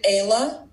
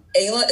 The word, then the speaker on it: Ayla: My name is Ayla Harris, loan officer with First Home Mortgage. You can find me on Facebook and Instagram at Approved with Ayla. Ayla is spelled A-Y-L-A.